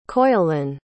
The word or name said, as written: coilin